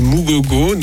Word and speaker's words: Mugogo: ensemble, où est-ce qu'on peut le voir alors il y a tout qui est sur YouTube et il suffit de taper Mugogo, donc comme ça se prononce, et le documentaire s'appelle Mugogo Rising, R-I-S-I-N-G et M-U-G-O-G-O.